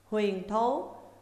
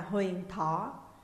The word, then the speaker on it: Huyền Thố: Huyền Thố cũng đọc là Huyền Thỏ, tên một quận ở Bắc Trung Quốc, gần các tỉnh Sát Cáp Nhĩ và Nhiệt Hà, đây là chỉ Liêu Đông.